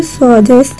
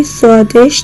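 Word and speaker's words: Swades: ناسا که محبوبیت جهانی اون رو میدید، اجازه داد تا فیلم برداری سوادس یا با تلفظ درست سوادش در اونجا انجام بگیره.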